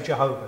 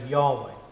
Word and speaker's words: Jehovah: And there's two current pronunciations, but we're going to say Jehovah, because that's the traditional translation. Modern people, some people say Yahweh, but we'll say Jehovah.